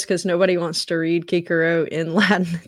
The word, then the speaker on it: Cicero: He does pronounce Cicero, but Kikero. The C is a little weird in Latin, so just in case you're hearing that, you think it's weird.